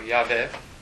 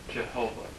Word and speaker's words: Yahweh: We don't know 100% for sure how to pronounce that. Usually it's pronounced Yahweh, and that's probably pretty close. Or Yahweh. Another way you'll see that is sometimes Jehovah.